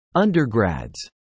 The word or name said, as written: undergrads